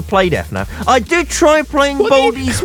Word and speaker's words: FNAF: Yeah, I think it's normally pronounced together FNAF.